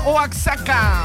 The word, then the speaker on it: Oaxaca: Ahí en Juchitán, Oaxaca.